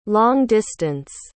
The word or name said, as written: long-distance